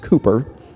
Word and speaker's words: Cowper: If you've seen his name before, you probably think it's William Cowper, but it's actually pronounced Cooper.